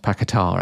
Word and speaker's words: Paqueta: Lucas Paqueta, I think it's pronounced Paqueta, actually.